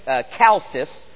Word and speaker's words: Chalcis: Licinius was actually the ruler of, and I think this is pronounced with as a K, Chalcis, some 50 years earlier.